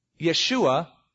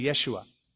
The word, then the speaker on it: Yeshua: Yeshua, we would call it Joshua. Joshua, it was a common Hebrew name, except the Hebrews don't pronounce the J, they say Yeshua.